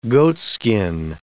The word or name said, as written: goatskin